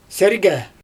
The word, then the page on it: sergE: Xyzyl